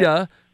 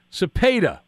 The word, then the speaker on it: Cepeda: Cepeda. Cepeda. Orlando Cepeda. That's how you say it.